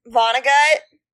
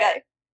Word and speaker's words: Vonnegut: I feel like anything by, Vonnegut. Why did I say it like that? How do you say his name? – That is how you say it. Okay. Vonnegut.